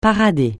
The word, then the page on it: parader: francais